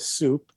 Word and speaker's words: SEWP: It's NASA SEWP, but it's pronounced NASA SOUP, GSA contracts, the DOD Enterprise Software Initiative, the DOD ESI contracts, and likes like that.